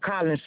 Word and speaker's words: Collin: C-O-R. – C-A-R-O-L-Y-N. And Colin, C-O-L-L-I-N.